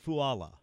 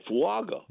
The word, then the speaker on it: Fuaga: Because, the years he's played at Oregon State and even Senior Bowl, Combine, they always said Fuaga. Mm-hmm. But, he told us last night, the G is silent. But he said, if you want to call me Fuaga, that's good.